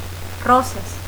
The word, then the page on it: Rosas: Spanish